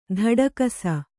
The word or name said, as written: ತೊಡಕಿಸು